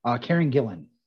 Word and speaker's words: Gillan: Is it Gillan? – Gillian? – Yep. – Right. – Amy Gillian? Pond. – Yes. I'm looking to you, for the pronunciation on that one. – Karen Gillan.